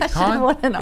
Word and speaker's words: Cannes: Con is spelled C-A-N-N-E-S.